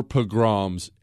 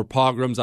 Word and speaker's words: pogroms: There were pogroms everywhere, or pogroms. I've heard it pronounced both ways.